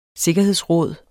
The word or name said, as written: sikkerhedsråd